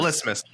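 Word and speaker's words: Vlismas: I'm gonna butcher Vilsma. – Vilsmas. – No, I think rhymes with Christmas. V L I S.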